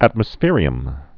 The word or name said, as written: atmospherium